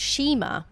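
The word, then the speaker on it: nshima: So this food is called shima, and it's spelt with a silent N on the start of it.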